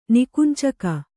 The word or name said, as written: ನಿಕುಂಚಕ